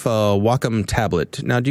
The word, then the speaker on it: Wacom: When I go to SIGGRAPH, I'd go to the booth and they would always chastise me and say it's called Wacom.